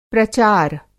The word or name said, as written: प्रचार